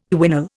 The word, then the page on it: quinol: Indian